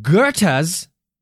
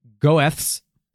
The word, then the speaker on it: Goethe: In Goethe's, spelt the stupid German way, which should be pronounced Goeth's, but it's pronounced Goethe, right? right. Goethe G O E, Goethe drama, and many subsequent versions of the story, Mephistopheles helps Faust seduce a beautiful and innocent girl, usually named Gretchen.